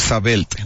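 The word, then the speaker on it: Sabelt: Si lo vamos a pronunciar en español, lo correcto es decir sabelte, ¿no?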